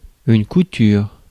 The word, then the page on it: couture: France; [ku.tyʁ]